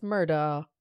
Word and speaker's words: Murdaugh: What's the Murdoch files? – Murdaugh. I'm saying it wrong. Everybody, it's Murdaugh, not Murdaugh. – It's murder, like murder. – Murdaugh. – Like it's murder. – M-U-R-D-A-U-G-H, Murdaugh.